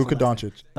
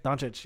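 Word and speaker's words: Luka Dončić: But Porzingis and Luka Doncic. I can't pronounce Luka Doncic. – Doncic, yes.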